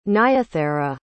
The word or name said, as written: nyathera